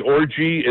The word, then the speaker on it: Orji: And when I say Orji, it's O R J I. Is the way his last name is spelled, Orji, O-J-R-I.